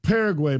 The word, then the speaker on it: Paraguay: So we are now Paraguay, by the way. Is that how you say it? – Yes.